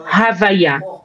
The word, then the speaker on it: Havaya: Keter es la punta de la Yud, y el resto es Yud, Kei, Vav, Kei. Pero se pronuncia Havaya.